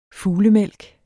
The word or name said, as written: Ornithogalum